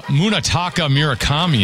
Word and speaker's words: Munetaka Murakami: Munetaka Murakami in the 8th.